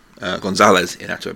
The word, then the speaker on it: Iñárritu: Iñárritu, González Iñárritu, I believe it's pronounced.